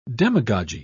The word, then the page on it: demagogy: British English